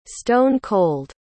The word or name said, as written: stone-cold